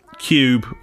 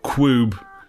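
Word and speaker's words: Qube: But I have been playing Cube or Quube. – Quub. – Depending on how you want to pronounce Q-U-B-E. I'll go with Quube.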